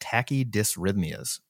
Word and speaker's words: tachydysrhythmias: Now, you said here, you linked a study, the iStop AFib randomized controlled trial, and you talk about tachydysrhythmias.